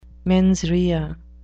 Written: mens rea

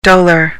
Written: dolor

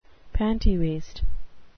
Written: pantywaist

